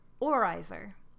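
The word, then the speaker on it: oorijzer: So what I've gathered from looking at Dutch pronunciation online and asking people who speak Dutch, it's pronounced oorizer. – Because that's how it's spelled.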